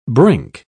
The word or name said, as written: brink